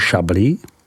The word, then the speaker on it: Chablis: V nejsevernější části nalezneme region Chablis, vyslovuj Chablis. Ten má ostatně geograficky blíže Champagne, vyslovuj Champagne, než k Burgundsku. Od prvních burgundských vinohradů jsou ty ze Chablis vzdálené více jak 100 kilometrů.